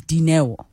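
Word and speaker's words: Dineo: For example, the Basotho people, the L is pronounced D. So it'll look like Linewo, but it is Dinewo.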